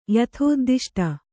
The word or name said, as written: ಯಥೋದ್ದಿಷ್ಟ